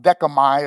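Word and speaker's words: decamai (δέχομαι): That word accept in the original language, the English transliteration would spell out decamai is how you pronounce it.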